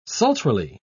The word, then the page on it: sultrily: British English